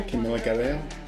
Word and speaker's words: Camila Cabello: Camila Cabello.